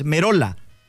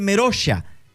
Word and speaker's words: Merolla: Porque La pronunciación del apellido de Lucas en italiano, porque el apellido de italiano es Merola, pero el jugador dice Merosha.